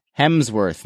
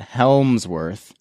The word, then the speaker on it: Hemsworth: He also let me know when we were talking about extraction that it's pronounced Hemsworth, not Helmsworth.